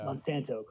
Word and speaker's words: Monsanto: I think you start bleeding into the lines of Monsanto. Mm. Is that how you say it? I forget. – Yeah, Monsanto, I think. Yeah. – Monsanto, correct, yeah.